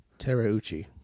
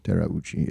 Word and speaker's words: Terauchi: It's Tara, Tera Uchi. So Tarauchi. – Tarauchi. Yeah. That's what I imagine.